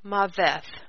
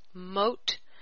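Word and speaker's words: Mot (מות): Now, the Strongs and Brown Driver Briggs say that number 4194 is pronounced Maveth, and I don't believe that's the case. The Jews, there may be some Jews that pronounce this Maveth, but I believe that, you know, I've heard people pronounce it, and it actually is Mot, Mem Vav Tav Mot.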